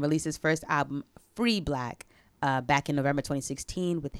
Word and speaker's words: 6LACK: Who's pronounced Six Black to some people, but it's Black.